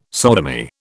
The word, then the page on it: sodomy: British English